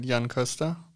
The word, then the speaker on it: Jan Koster: I would say Jan Koster, but that's a probably pretty German pronunciation, so...